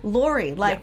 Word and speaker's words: Luery: How do you say the middle name? – Lori. – Like L-O-R-I. – Yes, but spelled poorly. – Like a British truck. – L-U-E-R-Y. – Correct. – L-U-E-R-Y.